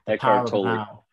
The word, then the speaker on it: Eckhart Tolle: I tell you, there's a book by a guy called Eckhart Tolle. I think that's how you pronounce his name. – Eckhart Tolle.